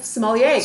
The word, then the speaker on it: sommelier: Sommelier? – Sommelier. – Sommelier. – Yeah. – Okay, that was close. – That was good. You did a great job. – Okay. Yeah. sommeliers.